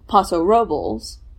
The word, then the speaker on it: Paso Robles: Because the residents, you know, the ones who have nothing to do with the wine industry say Paso Robles, which is, you know, not the Spanish pronunciation.